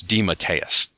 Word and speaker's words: DeMatteis: And yes, that is apparently how you pronounce his last name. I asked him on Twitter. It's DeMatteis.